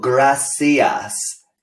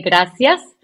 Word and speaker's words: gracias: You know, gracias or gracias? Because it's spelled with a C, well, right? But is it pronounced, is it th? – No, because, in my country, I'm from Latin America. So we say gracias. And then people from Spain say gracias.